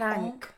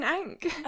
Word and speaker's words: ankh: An ankh. – It is an ankh. – I believe so. Like the Egyptian symbol that Iris had. – Yeah. – I think it's an ankh.